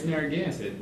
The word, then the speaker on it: Narragansett: Do we say a Narragansett or Narragansett or how? – As a former resident of Narragansett, yes. – I am going to say it's Narragansett.